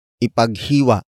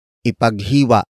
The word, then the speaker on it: ipaghiwa: Ipaghiwa, mo, siya, nang, lechon. Ipaghiwa, mo, siya, nang, lechon.